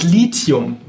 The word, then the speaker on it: Lithium: Direkt auch gleich mal Lithium, das wird oft auch als Lithium ausgesprochen. – Was ist denn eigentlich richtig? Lithium oder Lithium? – Genau, es ist Lithium, weil es kommt von dem altgriechischen Wort Lithos, was so viel wie Stein bedeutet.